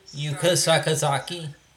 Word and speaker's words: Yuka Sakazaki: Yuka Sakazaki. – Yuka Sakazaki, thank you. I almost mispronounced that grotesquely, so thank you.